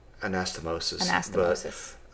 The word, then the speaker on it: anastomosis: Anastomosis, is that how do you say that? – Anastomosis.